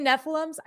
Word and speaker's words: Nephilim: He's had a question, and his question is, do you think that the reference to Gilgamesh, okay, and the Nephilim, how do you say Nephilims? I say them Nephilims. It's Nephilim. That's good. That's how you say it.